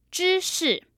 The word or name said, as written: zhishi